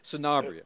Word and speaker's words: Sanabria: And it's Sanabria. – Sanabria.